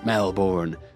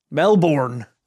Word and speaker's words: Melbourne: You join us at the legendary Princess Theatre on Spring Street, Melbourne, the oldest theatre in Australia.